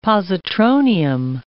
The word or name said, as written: positronium